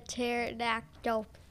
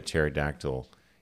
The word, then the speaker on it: pterodactyl: I think the joke is, why can't you hear the pterodactyl use the bathroom? – Oh, yeah, because the pee's silent. – Because the pee is silent.